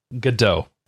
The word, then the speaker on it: Godot: Godot is how you Godot. Pronounce that. – Sorry. – Yeah. It's a French name.